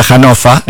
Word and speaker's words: Hannover: Había un restaurante en se escribe Hannover, se pronuncia Hannofa en alemán.